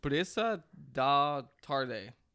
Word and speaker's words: Brisa da Tarde: It's Brisa da Tarde. – Is that right, Merk? Our pronunciation has experience. – Yeah, that was all right.